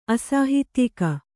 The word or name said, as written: ಅಸಾಹಿತ್ಯಿಕ